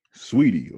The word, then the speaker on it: Saweetie: Sweetie.